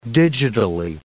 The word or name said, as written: digitally